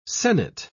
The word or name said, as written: sennet